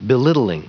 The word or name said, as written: belittling